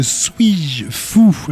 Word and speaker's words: S8JFOU: Et on va commencer avec Suis-je Fou. Alors ça s'écrit S-8-J-F-O-U.